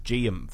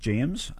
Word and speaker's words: Geum: And Annie from Dolby commented, to say, I've just discovered, and I believe it's pronounced Geum flowers, and love, is that right? – G-E-U-M? – G, yes. – Yeah, righto, yep.